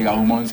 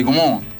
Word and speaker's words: Gaumont: Debo decir que no se dice Gaumont, sino que se dice Gaumont.